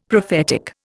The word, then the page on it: prophetic: Indian